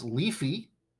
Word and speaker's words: Leafve: It's leafy, and this is how you're going to find it. It's spelled L-E-A-F-V-E.